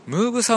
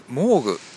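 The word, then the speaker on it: Moog: これはですね、マイコミジャーナルさんに載ってたのかな、ムーグサウンドを実現する iPad 用シンセサイザーアプリアニムーグがリリース。これムーグ、モーグ、まあ、いろんな読み方あると思うんですが、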